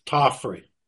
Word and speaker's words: Tofferi: First off, Ken, how do you pronounce your last name? – It's Tofferi.